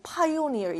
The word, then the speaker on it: pioneer: 자, 이거 발음 pioneer 이라고 읽는 거야.